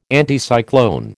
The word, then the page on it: Anticyclone: American English